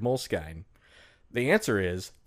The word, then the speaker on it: Moleskine: The moles, M-O-L-E-S-K-I-N-E. Mm-hmm. How did you pronounce it? – Moleskine, and I didn't know how to pronounce it.